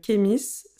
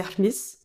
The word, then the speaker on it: Khemis: Ça se dit Ahmis. Ok. – En vrai, mais tout le monde prononce Kémis. Il y a même des gens qui disent Kémi. Ça me gêne pas du tout.